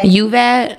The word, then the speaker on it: Uvalde: Is it Uvalde? – Uvalde.